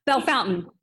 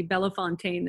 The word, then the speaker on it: Bellefontaine: So if you're pronouncing it, I don't even know how you pronounced it, but if you say that, or if you say Bellefontaine, then people will know you're not from there.